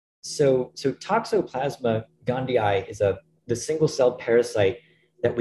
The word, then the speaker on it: Toxoplasma gondii: Yes, Toxoplasma gondii.